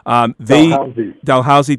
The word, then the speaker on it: Dalhousie: As a sidebar, in 2010, there's a university in Canada, I may say their name wrong, Dalhousie. Dalhousie, thank you,